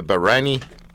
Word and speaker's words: Bahraini: Bahraini Pearl Divers.